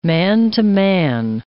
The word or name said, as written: man-to-man